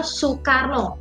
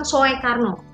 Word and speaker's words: Soekarno: Misal Soekarno kan Soekarno Oh, oe-nya itu jadi u.